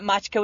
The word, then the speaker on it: matcha: Not matcha with a K? – Nope. – Oh. – Not with a K. – Oh. – With an A. – There's no no silent C. – It's matcha.